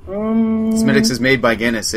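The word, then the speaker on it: Smithwick's: It looks like Smithwick, but you don't pronounce it that way.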